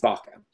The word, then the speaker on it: Vaca: So the Spanish pronunciation is Vaca. And Vaca means cow in Spanish.